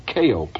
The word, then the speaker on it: Keio: or Keio Plaza, it's more properly pronounced. It's spelled K-E-I-O, and it's the tallest building in Japan.